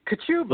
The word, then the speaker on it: Kachuba: I tell people to sneeze. It's Kachuba. – Kachuba.